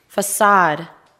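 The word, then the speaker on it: façade: facade, facade. – 건물의 정면, 외관. 자, 프랑스 마을에서 온 거예요. 거기 보면은 C자 밑에 이렇게 뭐가 달려 있죠. 자, 요렇게 생긴 거는 발음을 S 발음으로 읽어요. 스라고 읽어요. 자, facade라는 것은 건물의 정면이나 외관을 말하는데 facade의 어원은 face, 얼굴이에요. 써보세요. F-A-C-E.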